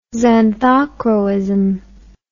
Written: xanthochroism

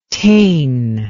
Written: taen

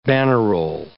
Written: bannerol

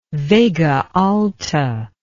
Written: Vega Alta

